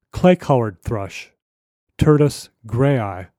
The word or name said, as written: Clay-colored Thrush